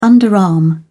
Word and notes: underarm: British English

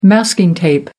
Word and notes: masking tape: American English

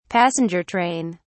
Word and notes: passenger train: British English

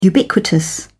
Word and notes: ubiquitous: British English

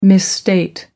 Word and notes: misstate: American English

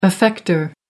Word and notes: effector: American English

